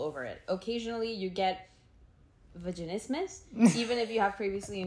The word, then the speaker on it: vaginismus: Vaginismus.